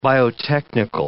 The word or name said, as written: biotechnical